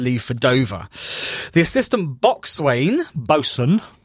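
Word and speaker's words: boatswain: Why is it written boxwain? – Because that's how they spell it, because nautical people do things differently. – Okay. – Bosun. – I've never in my life known that the word bosun was written boxwain.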